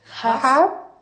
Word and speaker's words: half: Half past. – Se escribe H-A-L-F, pero no pronuncio la L.